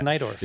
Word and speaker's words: Najdorf: Najdorf. Najdorf. Pronounced Nidorf.